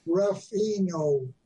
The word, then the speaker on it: Ruffino: I pronounce it the way it's spelled. Ruffino. I-F-O-I-E-N-O.